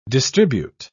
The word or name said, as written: distribute